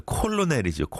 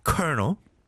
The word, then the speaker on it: Colonel: Colonel, C-O-L-O-N-E-L. 이걸 누가 Colonel이라고 생각하겠어요? 콜로넬이죠, 콜로넬. 그죠? But you pronounce it Colonel. 그 다음에 또 이제 R과 L의 조합은 참, 아, 너무 힘들죠. Rural, rural, 시골. R-U-R-A-L, rural.